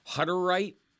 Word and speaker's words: Hutterite: And I was struck by the, and you got to tell me how to pronounce this, Hutterite? – Yes, that's right.